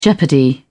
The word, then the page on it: jeopardy: British English